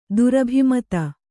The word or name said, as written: ದುರಭಿಮತ